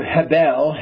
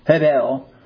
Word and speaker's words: Hebel: Abel, the Hebrew Hebel, you don't pronounce it, it's a breath H, Hebel, or Abel, which means breath or vanity, was murdered by Cain in an act of jealous rage.